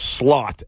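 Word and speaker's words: Slaught: It's Slott, S-L-A-U-G-T. Get your head out of the gutter. That's like Slaughter? – How it's spelled. – Like Sergeant Slaughter? – Yeah, exactly that way, but without the E-R.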